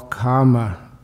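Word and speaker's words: kāma: Now the first of those wantings, they call karma tanha. Karma tanha is K long A M A, singular.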